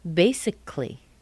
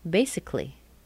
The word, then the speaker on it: basically: That A in T-A-L-L-Y. In American English, it's pronounced tli. Accidentally. Accidentally. Basically. Basically. C-A-L-L-Y, that A is not there. Basically. Anytime you see C-A-L-L-Y in American English, you'll pronounce it cle.